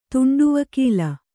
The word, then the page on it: ತುಂಡುವಕೀಲ: Kannada